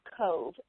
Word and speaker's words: Cobh: Cobh, and it's spelled C O B H.